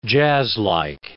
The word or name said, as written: jazzlike